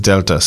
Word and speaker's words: Delta: To clear this very important issue up, I had to ask Konami's public relations how we should pronounce the game's name, and the answer is Delta, so it's called Delta.